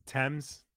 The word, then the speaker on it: Thames: It's pronounced Thames, the river Thames.